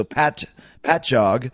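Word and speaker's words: Patchogue: At the 18th, it's 89 North in Pajog, New York. So, somebody scolded me for my pronunciation of that. Hopefully they said it's P-A-T-J-O-G. So Pajog. Sorry if I'm still butchering that.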